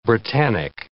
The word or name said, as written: Britannic